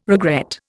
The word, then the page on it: regret: Indian